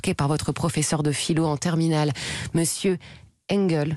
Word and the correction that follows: Engel: j'espère que je prononce Angèle. – Angèle, voilà, j'ai voulu me faire à l'américaine, mais non, Angèle.